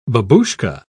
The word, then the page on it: babushka: British English